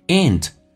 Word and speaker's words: and: And 可以说成 and 这个发音， D 不用发音。